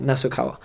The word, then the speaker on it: Nasukawa: That's it, Nasukawa.